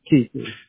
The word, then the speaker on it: Keithley: Keithley. – Could you spell that? – K-E-I-T-H-L-E-Y. – You said K-E-I-C, like Charlie H, like hotel, L-E-Y? – No, T as in like tiger, T-H-L-E-Y.